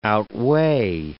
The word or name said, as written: outweigh